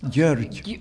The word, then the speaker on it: Gyorgy: You have to learn how to pronounce his first name. Gyorgy.